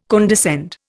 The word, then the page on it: condescend: Indian